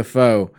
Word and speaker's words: FOW: So, Studio FOW, or Studio F-O-W, I don't know the correct pronunciation for that one. Thank you. Studio Faux.